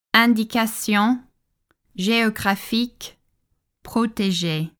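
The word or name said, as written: Indication géographique protégée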